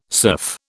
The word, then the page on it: serf: British English